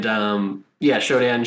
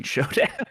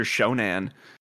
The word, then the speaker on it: SHODAN: I apologize for mispronouncing Shodan. – I think I called her Shonan.